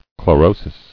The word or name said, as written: Chlorotic